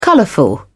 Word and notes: colorful: British English

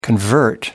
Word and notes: convert: American English

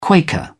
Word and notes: quaker: British English

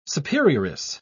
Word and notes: superioress: British English

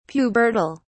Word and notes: pubertal: British English

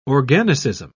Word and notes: organicism: British English

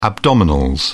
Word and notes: abdominals: British English

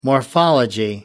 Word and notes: morphology: American English

